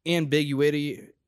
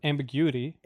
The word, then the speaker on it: ambiguity: There's like ambiguity. Is that a word? – Ambiguity. – Ambiguity. Sorry. – You just emphasized on the wrong syllable.